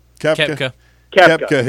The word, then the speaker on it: Koepka: Koepka had made that birdie putt.